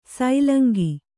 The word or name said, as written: ಸೂಲಂಗಿ